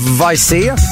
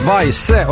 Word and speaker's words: Weise: Imorgon så kommer Andreas Weise. Nej, jag skojar, Weise och hälsa på.